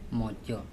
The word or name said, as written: mocO